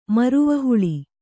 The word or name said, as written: ಮುರುವನಹುಳಿ